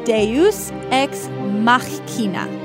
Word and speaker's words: Deus ex Machina: It is Deus ex Machina.